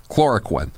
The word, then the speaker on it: hydroxychloroquine: So let me ask you first about this old malaria drug, hydroxychloroquine. Is that how you pronounce it? Chloroquine. – Yep. – Like quinine, right? – Go ahead.